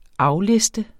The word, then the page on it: afliste: ˈɑwˌlesdə